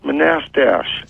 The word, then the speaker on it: Manastash: Do you know where Manistash? – Yeah, it's pronounced Manastash.